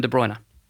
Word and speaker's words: De Bruyne: So I call him Kevin De Bruyne. – Yeah, De Bruyne. Okay, good. Thank you very much.